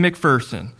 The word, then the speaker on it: McPherson: And so how to remember how to pronounce McPherson is that there's no fear in McPherson.